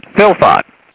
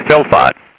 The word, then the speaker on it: fylfot: So I thought, F-Y-L, and then fot, I'll do, let's see, fot rhymes with lot, then got, and hot. So I'll say fot is F-O-T. Let's try that. So I said, philfot, F-Y-L-F-O-T, philfot.